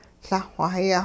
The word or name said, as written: Lah-xw-hayah